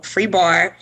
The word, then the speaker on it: FREEBARRE: You have FREEHOT, FREEHOT, free bar, and correct me if I'm saying that wrong, free bar, yes. – FREEBARRE.